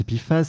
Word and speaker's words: PFAS: Et donc, pour commencer, de quoi parle-t-on Qu'est-ce que c'est que les PIFAS alors, les PFAS, les PFAS, donc à prononcer PFAS, c'est déjà quatre lettres pour une famille de produits chimiques franchement pas facile à prononcer.